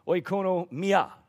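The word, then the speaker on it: Oikonomia (οἰκονομία): I'd probably butcher the saying, but oikonomio. How's that? Oikonomia is what it actually comes from.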